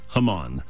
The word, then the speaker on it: Haman: Now, in English, we pronounce his name Haman. All right. In Hebrew, it's Haman. So it sounds a little different, because for most of us, if you've read the story of Esther all your life, you're thinking Haman.